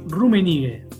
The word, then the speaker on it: Rummenigge: Ayúdame a pronunciar los apellidos alemanes que me cuesta un poco. Rumenig. – Karl Heinz Rummenigge. Excelente.